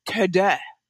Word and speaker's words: Teux Deux: But he was like, "Tada." Is how they would say it. So we spelled it out how the French would pronounce it, which is T-E-U-X-D-E-U-X.